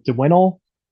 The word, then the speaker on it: Dzwinel: D-Z-W-I-N-E-L. And I'm pretty sure like the Z is silent, or you pronounce it for like an I or a J. So Dinwinnel.